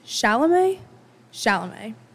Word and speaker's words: Chalamet: Timothy how do you even pronounce that? I think it's Shemalamale, but I realize that that's not actually correct. Chalamet.